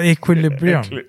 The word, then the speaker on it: Equilibrium: Och den heter, jag kan inte ens uttala, Equilibrium. – Ja, Equilibrium.